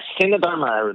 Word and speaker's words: CineDharma: Am I pronouncing that right? Cinema Dharma? – Yes, Cinema Dharma. That's right.